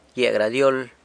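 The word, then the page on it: gyia gradyol: San Lucas Quiaviní Zapotec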